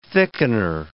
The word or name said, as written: thickener